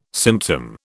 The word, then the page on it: symptom: British English